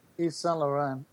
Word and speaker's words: Yves Saint Laurent: Yves Saint Laurent. – Yves Saint Laurent? – Yves Saint? – Yves Saint? – Well, you don't say the T in Saint. – Yeah, but Yves Saint Laurent? – It's Yves.